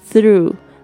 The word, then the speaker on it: through: 他说 through travel, 透过旅行 through, T-H-R-O-U-G-H, 特别注意一下这个 TH 的发音，这个舌头夹在这个两排牙齿之间的这个气音 through, through, GH 没有发音，然后呢跟这个 R 要有很好的连接哦。